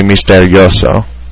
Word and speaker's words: misterioso: Yeah, because I was thinking misterioso, but it's actually misterioso, because it's a diphthong.